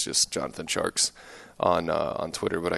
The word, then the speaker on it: Tjarks: And then he, uh, yeah, it is Jonathan Tjarks, T J A R K S.